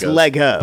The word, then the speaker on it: Lego: But apparently, it's Lego. – But it's the same thing, right? It's Lego. It's Lego blocks. Isn't there like a whole? – Yeah. – Like we're saying it wrong because they're not called Legos. They're Lego.